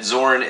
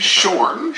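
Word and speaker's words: Xorn: Should Zorn, or I've recently heard that Zorn should be pronounced Shorn. – Shorn. – Yeah. Apparently. – If it's supposed to be pronounced like the letter X is pronounced in other Chinese words.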